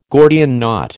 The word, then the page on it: Gordian knot: American English